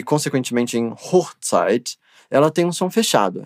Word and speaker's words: Hochzeit: Hochzeit.